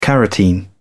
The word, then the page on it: carotene: British English